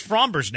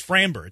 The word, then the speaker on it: Framber: Doesn't matter how he pronounces Fromber's name wrong. He says Framber. It's Fromber.